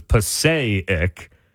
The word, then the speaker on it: Passaic: Yeah, he's taking away a syllable. He's putting the emphasis on the first one, not on the A, because it's Passaic.